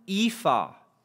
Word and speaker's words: ephah: She continues gleaning to the end of the day, and it says that she had acquired one ephah of barley. Ephah is how you say that.